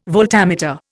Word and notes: voltameter: Indian

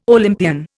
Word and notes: Olympian: Indian

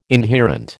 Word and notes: inherent: American English

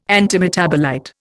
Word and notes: antimetabolite: Indian

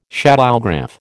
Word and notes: shadowgraph: American English

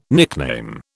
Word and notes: nickname: British English

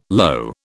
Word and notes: lo: British English

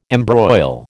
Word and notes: embroil: American English

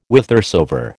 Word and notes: whithersoever: American English

